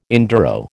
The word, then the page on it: enduro: American English